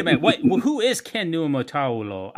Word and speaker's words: Niumatalolo: Is that how you pronounce his name? – Yeah, pretty close. Niumatalolo.